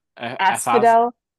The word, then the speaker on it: Asphodel: Asphodel. – Asphodel.